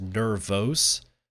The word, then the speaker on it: Nervos: It's actually, I think it's actually supposed to be pronounced Nervos, N-E-R-V-O-S, but I'm just going to say Nervous.